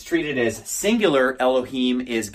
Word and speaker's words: Elohim: Well, in Aramaic, it's the exact same equivalent word, just instead of pronouncing it Elohim, it's pronounced Elahin, and it's, you know, you can see how close that is.